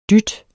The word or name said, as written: dyt